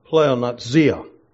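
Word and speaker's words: pleonexia: The Greek word here in verse 15 that is translated greed, the Greek word is pronounced pleonazia.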